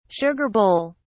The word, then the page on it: sugar bowl: British English